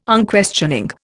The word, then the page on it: Unquestioning: Indian